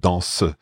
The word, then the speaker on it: danse: Because I'd always thought of danse as one syllable, but danse, it's kind of reinforcing this fact with song that you can actually stress that, not stress the second syllable, but recognize the second syllable.